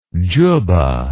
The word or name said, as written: jubbah